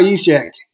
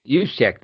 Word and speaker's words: Juszczyk: I'll play Kyle Juszczyk, or just, how do you say his last name? Juszczyk? – Oh, yeah, Kyle Juszczyk. – Juszczyk, that's how, Juszczyk.